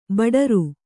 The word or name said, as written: ಭಟಾರ